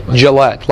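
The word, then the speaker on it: Jillette: Gillette, like the razor? Yeah. Okay.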